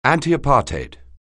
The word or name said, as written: antiapartheid